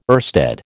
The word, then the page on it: oersted: American English